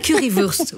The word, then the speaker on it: Currywurst: Oui, la Currywurst. – C'est Currywurst. Oui, c'est ça, c'est vrai. C'est Wurst.